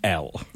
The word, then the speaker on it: Elle: Finally, and the contrast could not be greater, between a lovely, lovely, frothy cookery show and Elle. – Which rhymes with hell.